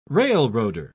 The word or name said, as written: railroader